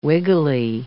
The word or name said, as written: wiggly